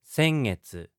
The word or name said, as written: sengetsu